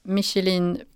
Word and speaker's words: Michelin: Marcus ställer frågan, Michelin Pilot Sport eller Primacy? Offrar man mycket komfort med Pilot Sport? Uttalade jag detta rätt på dessa travhästar? – Ja, du satte din harboj klockrent där.